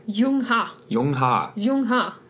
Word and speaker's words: Zheng He: And we're talking about a guy named, well, it's spelled Zheng He, pronounced Yung Ha. Sorry, I'm totally butchering that. – No, I think that's actually not bad.